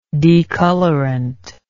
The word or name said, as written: decolorant